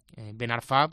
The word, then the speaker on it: Ben Arfa: Así que ya puntualizo ya de inicio para que todo el mundo lo tenga claro y que cuando nos escuchen decir Ben Arfa, pues que nadie se lleve las manos a la cabeza y diga que dicen estos. Así que nada, que todo el mundo ya sepa cómo es la pronunciación en el idioma original, en este caso en francés,